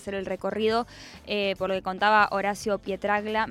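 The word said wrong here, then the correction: Pietragalla: Me cuesta mucho pronunciar su apellido. ¿Lo hice bien? No. Pietragala. – Pietragala, perdón.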